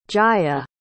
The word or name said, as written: jaya